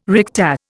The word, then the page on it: recta: Indian